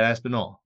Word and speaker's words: Aspinall: I asked him how to say it the when he was in New York for this event, UFC 295. And he said Aspinall. All.